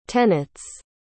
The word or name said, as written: tenets